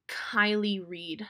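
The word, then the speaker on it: Kiley Reid: The author is Kylie Reed, I believe is how you pronounce her name. It's K-I-L-E-Y-R-E-I-D.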